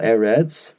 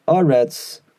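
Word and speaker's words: Eretz (ארץ): So, for example, the Bach here, you know, adds the first two Eretz with, you know, Segel, Eretz, but sometimes it's pronounced Aretz, and that is when the word appears at the very end of a pasuk, or has an Esnachta symbol under it indicating that there's a pause, there's the end of a phrase.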